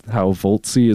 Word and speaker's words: Voltsea: And again, to clarify out there, if you're having trouble understanding how Voltsy is pronounced or what we're saying, it is VOLTSEA.